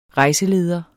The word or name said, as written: REJSELEDERE